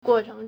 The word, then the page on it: 过程: guòchéng